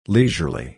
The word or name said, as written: leisurely